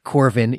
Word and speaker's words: Corvin: And Corwin, it's actually spelled with a V, not a W, so probably we're supposed to call it Corvin, even though a V in Latin is pronounced like a W. We'll call him Corvin, I guess.